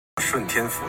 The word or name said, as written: shuntian